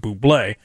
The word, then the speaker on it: Bublé: They enlisted Bublé in a convenience store scene that finds him desperately insisting customers should pronounce the product like his last name, Bublé.